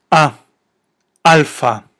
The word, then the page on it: ἄλφα: alfa